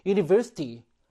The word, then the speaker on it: university: University. 시작되는 발음이 그래요. 모음 발음이 아니라 자음 발음을 시작하죠.